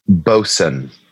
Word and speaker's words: boatswain: Bosun. – Bosun. He's the bosun. – Yeah. – Thank you. – It's spelled boatswain, but it's not it.